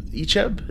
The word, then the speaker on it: Icheb: Icheb, is that how you say his name? – Yeah.